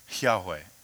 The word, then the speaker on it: Yahweh: So when you read the Old Testament, in the Hebrew language, there are no vowels, it's all consonants. And so when you take a look at that in, in the Hebrew, generally what we have come up with, scholars have come up with, is we pronounce it Yahweh.